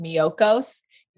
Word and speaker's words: Miyoko's: It's uh they don't sponsor me, but if anyone knows them, I would love to be their their spokesmodel I think it's pronounced Miyoko's. It, I believe it's Japanese, and it, they have a butter that is so.